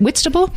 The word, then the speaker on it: Whitstable: Am I saying that right, Katherine? Is it Whitstable? You're saying exactly right, yeah.